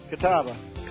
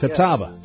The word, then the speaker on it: Catawba: Did I pronounce that town correctly? – You did. Catawba. – Catawba. Yes. Catawba. You got to put a little Southern on that.